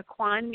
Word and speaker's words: Ikhwan: It's spelled IKHWAN in America. The Quan means brotherhood. So they're calling themselves in a Quan group now. it almost sounds like Kwanzaa or, you know, something.